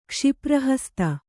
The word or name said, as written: ಕ್ಷಿಪ್ರಹಸ್ತೆ